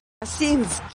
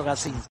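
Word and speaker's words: Gazinsky: Gacinski. – Correcto, ahí sí. – Pero Yuri lo dije bien. – Es como el gazebo, pero Gacinski.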